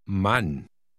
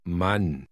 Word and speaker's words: Mann: Mann. Mann. – Notice that the letter A is pronounced differently in German than in English.